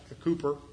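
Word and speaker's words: Cowper: Zechariah 13, 1, that great hymn of Cooper, is how you really say his name.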